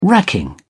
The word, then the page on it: racking: British English